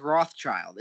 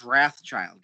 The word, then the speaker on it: Wrathchild: With his nothing, it's nothing like Rothschild. It's Wrathchild, guys.